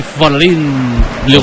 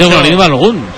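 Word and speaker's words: Folarin Balogun: Dice Folarín Balogun. – Tiene que ser Folarín Balogun, vamos.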